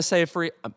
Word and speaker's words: Seyfried: Amanda Seyfried, rhymes with rye bread, in Mank.